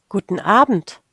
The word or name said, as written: guten abend